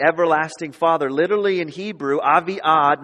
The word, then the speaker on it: Avi-Ad: So if you would spell it in our English kind of letters, it would be A-V-I and then a dash, A-D. Avi-Ad.